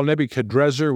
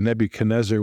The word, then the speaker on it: Nebuchadrezzar: Well, first of all, Nebuchadrezzar, which your Bible probably has, is actually probably the more accurate pronunciation, but we're also used to the Nebuchadnezzar, which the Hebraized transliteration leads us to. So I won't try to correct that, although if you're going to pick between the two, the Nebuchadrezzar is actually the, perhaps, scholars believe, maybe the more closely to the Chaldean pronunciation.